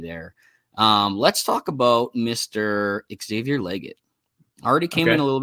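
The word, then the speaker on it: Xavier Legette: Also, real quick, bro, the correct pronunciation of his name, he corrected us there, Xavier Leggett. – Oh, emphasis on the E. Okay, Xavier Leggett.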